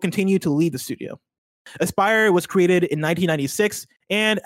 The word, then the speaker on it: Aspyr: Am I saying that right? Asper? Is it Aspyr? – Aspyr. – That makes more sense. A-S-P-Y-R.